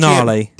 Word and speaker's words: Denali: Delaney? They let you know how it's pronounced. What's that? – Denali.